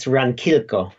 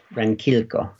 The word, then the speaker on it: Ranquilco: Is it Estancia Ranquilco? – It's Ranquilco, Ranquilco. Estancia Ranquilco.